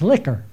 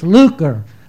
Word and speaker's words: lucre: you didn't get that pronounced right. That's liquor. I said, "No, Mom, it's not liquor. It's lucre. Lucre is money, not liquor."